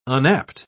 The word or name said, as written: unapt